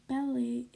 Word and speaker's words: ballet: Ballet is spelled bal- like ballet, but you say like ballet.